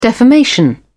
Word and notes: defamation: British English

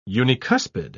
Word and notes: unicuspid: British English

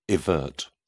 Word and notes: evert: British English

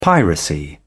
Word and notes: piracy: British English

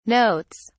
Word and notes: notes: British English

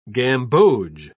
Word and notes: gamboge: British English

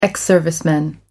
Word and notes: ex serviceman: American English

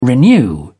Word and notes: renew: British English

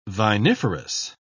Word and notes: viniferous: British English

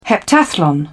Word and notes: heptathlon: British English

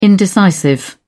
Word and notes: indecisive: British English

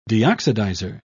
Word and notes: deoxidizer: British English

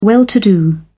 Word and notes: well to do: British English

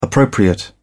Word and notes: appropriate: British English

